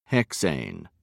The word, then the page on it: hexane: British English